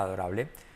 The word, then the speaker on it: adorable: O tenía pinta adorable. Her new dog looked adorable. Her new dog looked, pasado, U como o, letra clave L, looked adorable. Adorable. Letra clave L al final casi no se pronuncia.